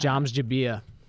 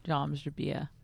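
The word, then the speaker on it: James Jebbia: Joms Jabia. That's how you actually pronounce his name. – Joms Jabia.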